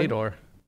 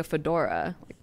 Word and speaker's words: Fedor: It was Fedor. – It could be. It's Fedor? – Yeah. Like Fedor Emelianenko. – I think the first time I pronounced it like a fedora.